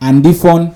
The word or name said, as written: Andifawn